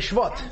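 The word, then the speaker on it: Shvat (שבט): He said Shvat. – And he told me to say Shvat. – Shvat. – This is technically the correct way to say it, because whenever you, there are many words that have a patach.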